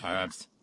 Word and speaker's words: herbs: So I pronounce the H in the herbs, like the English.